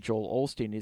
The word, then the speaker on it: Osteen: And that's a lot of the criticism a lot of people have of Joel Osteen, is he's more like... – Osteen. I don't think there's an L.